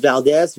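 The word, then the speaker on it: Valdes: My name is Emilio Valdez. That's spelled E as in Edward, M-I-L-I-O. Last name is Valdez, V as in Victor, A-L-D-E-S at the end, not a Z.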